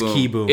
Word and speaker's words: Kieboom: Carter Kieboom is coming up. – Is it Carter Kieboom or Carter Kieboom? – It's Kieboom.